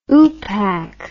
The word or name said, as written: oopak